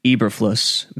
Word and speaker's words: Eberflus: Eberflus, Matt Eberflus, E-B-E-R-F, as in Frank, L-U-S,